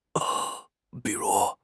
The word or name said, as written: bi3 ru2